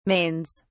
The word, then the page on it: mains: British English